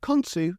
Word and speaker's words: Khonsu: I feel this has been done this way, as they were trying to spell out Khonshu as it is pronounced in the series, instead of Khonsu as it is actually pronounced in real life.